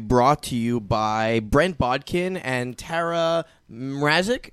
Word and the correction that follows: Mrakic: Is that how Mrakic. – You pronounce it? Mrakic. There we go.